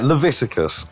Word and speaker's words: Leviticus: Leviticus, is that right? – Yeah, Leviticus, which is, if you've ever heard a Bible thumper try and like torture the gay community, they usually invoke the book of Leviticus.